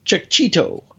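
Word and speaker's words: Checchetto: Whatever, is that how you pronounce? However you pronounce his name. Sorry, Marco, if I screwed it up. – I think that's very close. If not it. – Cicchetto. He's like a Cheeto.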